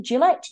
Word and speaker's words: Gillett: The referee for the game at the weekend, listeners, is going to be 36-year-old Australian-born referee Jared G.A. Is that how you pronounce it? Gillette? G.A.? – Gillett. – Thank you. I was trying to be all posh and G.A.